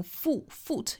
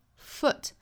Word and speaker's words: foot: 第二个词一般是要做弱读的， keep your foot 后面的这个 foot 里面的这个元音呢，应该是一个松口的松元音，所以读起来的话我们不要读成 foot，应该是 a foot，这个元音呢就是 B-O-O-K 书那个词里面的元音，所以说我们如果很熟悉那个词的发音的话，我们就把那里面的元音扣进来。